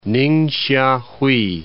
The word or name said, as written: Ningsia Hui